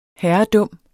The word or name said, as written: herredum